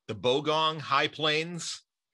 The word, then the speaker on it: Bogong High Plains: The Bogong High Plains.